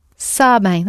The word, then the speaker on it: sabem: So how do you say they know? – Sabem. – Where are you going to put your stress? – Sabem. – Sabem, that's right.